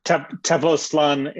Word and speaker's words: Tepoztlán: In addition, he is the co-founder of the Tepoztlán, I'm not going to say that one right. What's it? How would you pronounce it, Elliot? – Tepoztlán Institute.